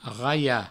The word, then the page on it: raiar: provençal; raia